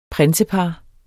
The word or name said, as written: prinsepar